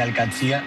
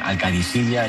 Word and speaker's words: Al-Qadisiyah: Bueno, se suele decir Al-Qatsia, pero también hay gente que dice Al-Qadisiyah, es que es difícil, ¿eh? Ah. No es fácil, pero Al-Qatsia es lo más fácil.